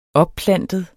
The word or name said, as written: opplantet